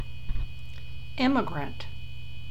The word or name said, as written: immigrant